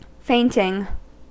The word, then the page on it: fainting: American English